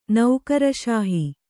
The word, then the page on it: ನೌಕರಷಾಹಿ: naukara ṣāhi; noun